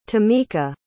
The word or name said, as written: tamica